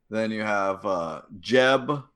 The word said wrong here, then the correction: Zeb: a Lasat. – Zeb. – Who's like, Zeb. – Zeb, sorry. But he's a Lasat.